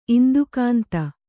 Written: ಇಂದುಕಾಂತಿ

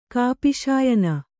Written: ಕಾಪಿಶಾಯನ

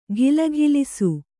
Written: ಘಿಲಘಿಲಿಸು

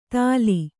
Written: ಥಾಲಿ

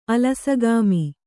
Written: ಅಲಸಗಾಮಿ

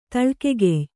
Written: ತಳ್ಕೆಗೆಯ್

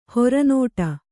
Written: ಹೊರನೋಟ

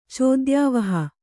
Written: ಚೋದ್ಯಾವಹ

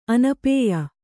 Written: ಅನಪಾಯ

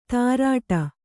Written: ತಾರಾಟ

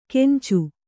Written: ಕೆಂಚು

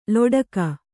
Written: ಲೋಟಕ

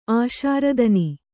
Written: ಆಶಾರದನಿ

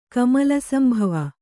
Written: ಕಮಲಸಂಭವ

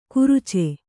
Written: ಕುರುಚೆ

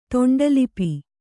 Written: ತೊಂಡಲಿಪಿ

